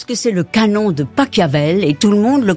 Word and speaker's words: Pachelbel: Que vous ayez dit Pachelbel.